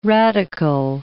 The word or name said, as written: radicle